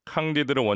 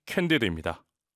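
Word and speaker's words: Candide: 프랑스 철학자 볼테르의 풍자 소설 캉디드를 원작으로 한 오페레타가 있습니다. 바로 20세기 최고의 지휘자 중 한 사람인 번스타인이 쓴 캔디드입니다. 캉디드의 영어 발음이 캔디드인데요.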